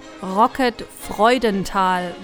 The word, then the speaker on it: Freudental: How do you pronounce that? – Rocket Freudental, which kind of like means Joy Valley.